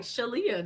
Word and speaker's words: ShaLeah: ShaLeah.